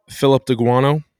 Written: Philip D'Aguanno

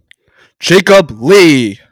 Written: Jacob Lee

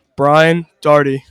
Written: Doherty

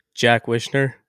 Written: Wishner

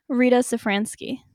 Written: Sefransky

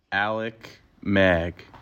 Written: Alec Maag

